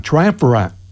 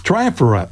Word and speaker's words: Triumvirate: Triumvirate. – That's it. – Triumvirate, 1973. We're going to play a couple songs from them. Lucky Girl and Million Dollars. Music from Triumvirate here on good old 89.3 WRTC Hartford.